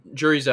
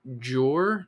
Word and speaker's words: Jure: It's like Jur, like sure, but with a J. – Okay. – Jur.